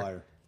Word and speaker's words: Mayer: Like, they ended up getting Marcelo Meyer. – Mm-hmm. – The A is silent. It's spelled Mayer, but it's pronounced Meyer. – Meyer. Got it.